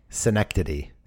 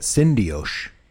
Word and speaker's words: Synecdoche: Synecdoche. Synecdoche. – I want to say it's synecdoche. – I was calling it syndioche. – Let's say that. Syndioche, New York. – No, we can't because that's not right.